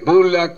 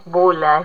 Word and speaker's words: Bulla: Sí, es bulla, es bulla. Es bulla y que viene del italiano, pero en realidad mis ancestros tendrían que haberse llamado bolla, que era el original.